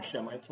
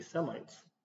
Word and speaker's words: Semites: And to this day, we call them Shemites, only we don't pronounce it that way. We say Semites.